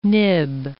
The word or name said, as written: nib